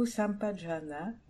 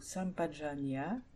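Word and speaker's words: Sampajañña: Le mot Sampajana ou Sampajanya, les deux prononciations sont possibles, comprend trois parties.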